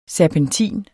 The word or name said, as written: serpentin